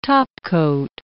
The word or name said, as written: topcoat